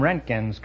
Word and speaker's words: Roentgen: Dr. William Rentgen's contribution to science and medicine is in everyday use. The name is spelled R-O-E-N-T-G-E-N.